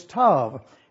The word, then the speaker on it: Tav: You will notice also that the last letter of the Hebrew alphabet is Tav, is the way we pronounce it, and you will see that it is presented to us on this diagram that we have for you.